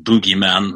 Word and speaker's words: bogeyman: And they were a little down on the Carpenter movie because of they pronounced it Boogeyman rather than Bogeyman.